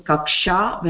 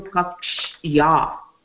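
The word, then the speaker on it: Kakshya (कक्ष्या): Kakshya, it's not Kaksha. Tatra, Yakara Hashruyate. You are supposed to pronounce Yakara, Yakaram also. Kakshya, that's all.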